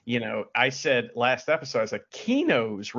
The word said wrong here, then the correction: Cooney: wrestling or karate school, but it's Cooney's karate school.